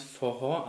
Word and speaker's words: Forró: Man hat mir erklärt, dieser Tanz Forró, also Betonung hinten und in der Mitte wird gesprochen wie ein H. – Ja. Geschrieben wird es Forro, also F-O-R-R-O. – Ja, genau, mit ähm, Akzent was, am Ende.